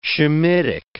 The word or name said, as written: Shemitic